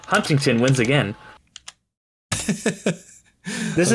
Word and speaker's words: Huntington: People call it Huntington. And I'm like, no, it's Huntington. People pronounce that T.